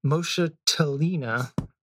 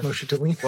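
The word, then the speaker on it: moschatellina: Adoxa moschatelina. Moschatelina. You accept that as a legit pronunciation? – Sure. – Moschatelina. – Well, I always tease my coworkers that the CH is K because it's Greek, but I'm minority, like Baldaskinus, Skenoplectus.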